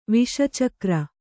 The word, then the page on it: ವಿಷಚಕ್ರ: viṣa cakra